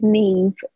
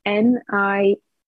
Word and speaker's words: Niamh: The best place to find me is Instagram, and that's Biofield, BIOFIELD, with Niamh. So my name is spelled NIAMH.